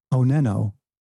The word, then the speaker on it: Oneno: And so then I pronounced that Oneno, and it's spelled O-N-E-N-O.